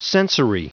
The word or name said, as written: sensory